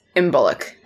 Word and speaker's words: Imbolc: But it's spelled I-M-B-O-L-C. And it's... – I think it's Imbuluk. That's how I've always heard it said.